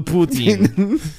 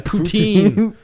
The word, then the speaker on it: Putin: We'll do it right in front of Putin. I think that's how they pronounce it. Poutine. – It's Putin.